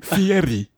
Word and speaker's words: Fieri: Fieri. You have to say it right.